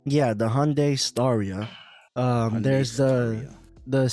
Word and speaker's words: Hyundai: Hyundai. – Ooh, Fred, they, good pronunciation. – Actually Hyundai, like Sunday.